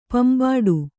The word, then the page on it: ಪಿಂಬಡು: pimbaḍu; kannada